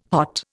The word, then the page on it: hot: Indian